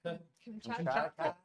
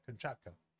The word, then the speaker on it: Kamchatka: Kamchatka? – How do you say that? – Kamchatka. – Yeah, Kamchatka.